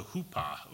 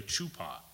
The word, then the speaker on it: huppah: And that bridal chamber is called a huppah, or you might also say it a chuppah. It's spelled either H-U-P-P-A-H or C-H-U-P-P-A-H. So it's acceptable in either ones.